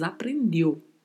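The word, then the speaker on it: aprendió: Atenção à pronúncia da letra O no final, porque é pronunciada com som fechado de O. Como tem um acento agudo, a gente pode ter a tendência de querer falar a vogal mais aberta. E não, pronunciamos aprendeu.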